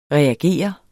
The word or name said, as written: REAGER